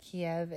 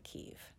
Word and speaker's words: Kyiv: Since our recording, we learned that Kiev is the traditional Russian pronunciation of the Ukrainian capital, and that the Ukrainian pronunciation is actually Kyiv.